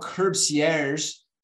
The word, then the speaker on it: Curbcierge: Here, Anne, is how Curbcierge service works. And it is important. They said in the headline that you have to say it like that.